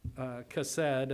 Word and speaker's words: chesed: But let's notice an example of this word, chesed, I believe it's pronounced, over in Psalm chapter 51, we understand that this is a psalm of repentance for King David after going into Bathsheba.